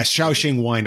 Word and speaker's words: Shaoxing: Is that Shaoxing? Is that how you say it? Yeah, Shaoxing wine.